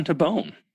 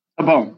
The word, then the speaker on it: Tabone: Welcome, John Tabone. Is that how you say it? Tabone, yeah. Tabone. Okay, I got it right.